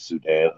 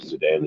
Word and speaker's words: Sudan: And Facebook is under my government name, which is Sudan, Aunu. Sudan is spelled S-U-D-A-N, and my last name is A-U-N-U.